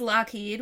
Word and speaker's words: Loughead: Alan Haynes Lockheed, which is spelled L-O-U-G-H-E-A-D, kind of like Loughead.